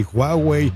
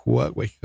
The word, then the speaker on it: Huawei: Huawei, etc, etc. Dije Huawei, perdón, Huawei, así se debe de pronunciar.